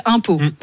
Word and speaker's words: Umpeau: Non, parce que la ville s'appelle Impôts. Ça s'écrit comme les impôts qu'on paye. – Non, ça s'écrit U-M-P-E-A-U.